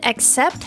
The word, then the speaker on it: accept: And the word accept has two syllables. Accept, accept. And the stress is on the second syllable.